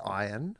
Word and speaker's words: Ian: Well firstly, his name is pronounced Ian because I have met Ian Ziering. I met him in Bondi. And I said, hello, Ian. And he said, thanks for saying it's Ian.